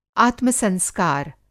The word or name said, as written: आत्म-संस्कार